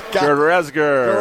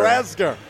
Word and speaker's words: Gerezger: Back to work now for Brookfield East is Dan Gerezger. – Gerezger. He's got to make it three syllables.